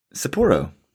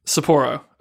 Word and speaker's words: Sapporo: Sapporo. – Sapporo.